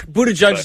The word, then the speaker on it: Buttigieg: My question, Buttigieg, and I'm probably mispronouncing his name. – That's Buttigieg is close enough, yeah.